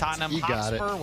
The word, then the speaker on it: Tottenham Hotspur: At Tottenham Hotspur, I don't know how to say it. – You got it. – That's correct. – Tottenham Hotspur, whatever that means.